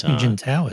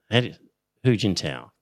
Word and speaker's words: Hu Jintao: Hu Jintao, isn't it? – Yeah. Hu Jintao.